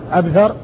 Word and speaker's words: عبثر: وفرق بين عبثر وعنبر إنما هو بالنقط، وأما الشكل فإنها متفقة، والفرق إنما هو بالنقط والاختلاف في الحروف بين الباء والثاء وبين النون والباء. الباء والثاء، الباء والثاء في الأول عبثر، والنون والباء في الثاني عنبر. فهذان لفظان متفقان في الصورة ولكنهما مختلفان في النطق، عبثر بن القاسم ومحمد بن سواء ابن عنبر السدوسي.